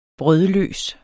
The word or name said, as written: brødløs